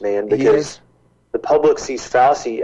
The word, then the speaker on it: Fauci: Fauci. Chi, like cheese. The moon's made of cheese. Everyone knows that. – Fauci.